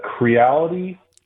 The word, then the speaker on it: Creality: And another printer that I'd suggest to other people is the Creality Ender 3. And it's spelled like reality with a C in front of it.